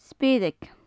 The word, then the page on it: speireag: gaelic